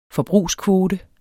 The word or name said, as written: forbrugskvote